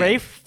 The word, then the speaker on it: Ralph: I mean, it's pretty close, but his name is spelled like Fiennes. – How do you say it? – Fiennes being fines is, you know, whatever. But Ralph being Rafe, I just don't understand. – He's Rafe Fiennes. – Rafe Fiennes. Yeah.